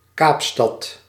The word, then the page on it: Kaapstad: /ˈkaːp.stɑt/